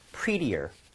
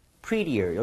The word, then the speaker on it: prettier: prettier. prettier. 여기 t에 지금 flap이 났죠? 가볼게요.